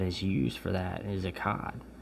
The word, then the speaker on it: echad: But the word one in this verse is echad. It's E-C-H-A-D. It's echad. Like, and the C-H is, I can't make that noise, so it's not pronounced like that, but it's E-C-H-A-D.